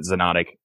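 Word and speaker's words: Xonotic: And it is spelled with an X. So it's X-O-N-O-T-I-C. So you pronounce it Zenotic, but it's spelled with an X.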